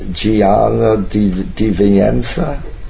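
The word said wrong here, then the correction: Di Venanzo: Gianni Di Vienza. – Di Venanzio.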